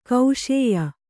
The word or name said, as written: ಕಷಾಯ